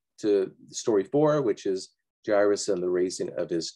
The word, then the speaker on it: Jairus: One of the rulers of the synagogue named Jairus, but it can be pronounced a number of different ways. I usually say Jairus because the A and the I in Greek, it's pronounced that way.